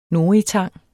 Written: noritang

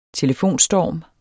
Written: telefonstorm

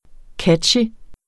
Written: catchy

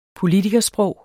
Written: politikersprog

